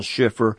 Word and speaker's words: Schiffer: Send a donation or write a check out to Paul Schiffer. My name is spelled S-C-H-I-F-F-E-R. That's Paul Schiffer.